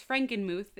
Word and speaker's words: Frankenmuth: It is Frankenmuth, if anyone was wondering. Thanks to Google, tra- eh, not translation, pronunciation. – so, now that we got that, Frankenmuth, Michigan and they brought her to the Lutheran cemetery that is was in her hometown.